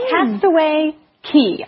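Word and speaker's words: Castaway Cay: Castaway Key. I know it's spelled as C-N-U-Y. Everyone thinks it's K. It is Key.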